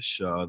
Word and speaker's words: Shah: My name is Shah. That's spelled S-H-A-H.